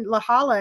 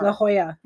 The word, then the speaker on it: La Jolla: She must not have been in La Jolla anymore. – La Jolla. – La Jolla. Why do I keep saying it wrong? – I don't know.